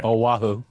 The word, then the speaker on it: Oahu: Oahu.